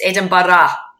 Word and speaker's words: Edinburgh: First off, I'm going to have to teach you how to say the word, you know, the city Edinburgh. It's Edinburgh. Come on. – Edinburgh.